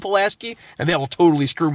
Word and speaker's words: Pulaski: It's pronounced Pulaski. We have a street here named Pulaski.